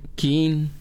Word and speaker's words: kin: Seis de la tarde, ya con 19 minutos, continuamos en Panorama Informativo Cancún, información que sirve nada más para que demos esa palabra kin. Kin. No, así se pronuncia, ya chequé, en maya, sol.